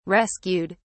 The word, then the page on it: rescued: British English